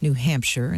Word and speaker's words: New Hampshire: The lawmakers also want to include an official pronunciation of New Hampshire in state law, making it clear sheer rhymes with fur.